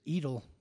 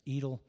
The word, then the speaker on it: aedile: aedile is how it's pronounced, I guess, aedile.